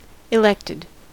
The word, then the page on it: elected: American English